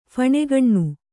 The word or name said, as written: ಫಣೆಗಣ್ಣು